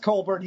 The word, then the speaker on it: Colbert: It's pronounced Colbert, he's Irish.